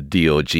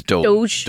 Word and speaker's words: DOGE: At one point, he's giving a presentation in the White House talking about the process of how the DOGE is getting on. The pronunciation of that is Doge. Doge, yeah.